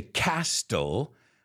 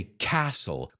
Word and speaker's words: castle: I don't say castle, I say castle. Como la T, no lo decimos.